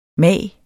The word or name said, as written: mag